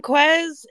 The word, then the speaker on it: Quez: Welcome, Quez.